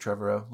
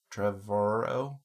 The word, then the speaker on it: Trevorrow: It's, looks like Trevorrow. Looks like it would rhyme with tomorrow. Trevorrow? – Whatever.